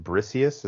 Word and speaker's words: Briseis: Number one, Rose Byrne in it as, is it Briseis? Is that how you pronounce her name? – Yeah.